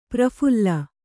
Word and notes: ಪ್ರಫುಲ್ಲ: praphulla

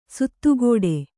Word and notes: ಸುತ್ತುಗಟ್ಟು: suttugaṭṭu

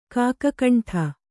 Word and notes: ಕಗ್ಗಂಟು: Kannada; kaggaṇṭu

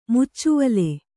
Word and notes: ಮುಚ್ಚುವಲೆ: Kannada

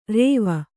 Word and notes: ರೇವ: rēva

